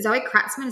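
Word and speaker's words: Zoe Kratzmann: Is it Zoe Kratzman? Is that how you say it? – Yes. Yep.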